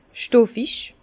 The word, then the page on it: stoffig: Bas-Rhin; Herrlisheim